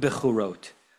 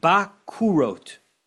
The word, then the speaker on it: bakurot: As if he says you should pronounce this not bechorot, but bakurot.